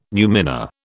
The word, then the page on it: numina: British English